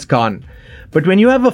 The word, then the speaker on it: Cannes: If you're having issues pronouncing the word Khan from Cannes Film Festival and, you know, you just can't seem to get it right, then looks like you have company.